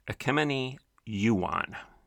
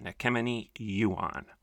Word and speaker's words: Ekemini Uwan: Latasha Morrison is also a close friend and associate of a woman, and I believe you pronounce her name, Ekemeni Yuan. I'm going to spell it if you want to look it up. E-K-E-M-I-N-I. U-W-A-N, Ekemeni Yuan.